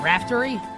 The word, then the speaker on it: Raftery: Ian Eagle and Bill Rafferty with the call this weekend. Raftery? I pronounced it incorrectly.